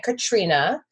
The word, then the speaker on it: Katarina: And our client, Katrina, her name is spelled Katarina, but she goes by Katrina Sviridova.